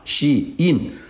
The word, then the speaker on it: SHEIN: 这些店呢的名字连中文名字都没有一个确定的，叫 SHEIN、啊、两个英文字合成的 SHE 女性的她 SHEIN 就是所在 SHEIN，读成中文翻译呢，译成喜英啊，这个喜英。